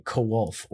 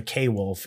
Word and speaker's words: Quolf: The Kowolf, or the K-Wolf, if you want to pronounce it incorrectly.